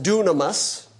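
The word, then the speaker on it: dunamis (δύναμις): Now, the second word that we need to be familiar with, I don't need to write it up on the board, but it's pronounced dunamis, and that is the word that is translated power.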